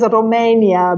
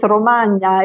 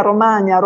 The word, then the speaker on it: Romagna: I used to live in Castrocaro, which is, as you pronounce it, as Romania, but it's Romagna, Emilia Romagna.